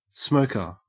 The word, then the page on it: smoker: Australian English